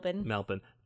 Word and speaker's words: Melbourne: now in Melbourne, and or Melbourne, or however you pronounce it. – Melbourne.